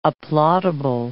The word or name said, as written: applaudable